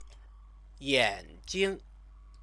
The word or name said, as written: yanjing